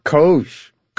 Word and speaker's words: COGE: How do you pronounce the acronym for this commission? – COGE. It is exactly what you think it is, Steve. And I know what's in the back of your mind when you ask that question. – Go on. What is in the back of my mind? – It does rhyme with Doge.